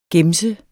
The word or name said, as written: gemse